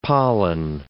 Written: pollen

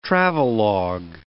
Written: travelogue

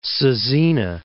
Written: Caesena

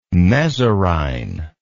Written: mesorrhine

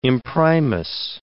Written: imprimis

